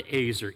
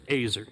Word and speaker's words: ezer: But it's this word Azer, E-Z-E-R is the way we would spell it in English, Azer, E-Z-E-R,